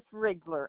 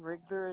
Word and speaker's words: Riegler: My name is Ruth Riegler, and Riegler is spelled R-I-E-G-L-E-R.